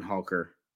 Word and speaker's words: Holker: Yeah, yeah, a Dallin Holker, H-O-L-K-E-R. – H-O-L-K.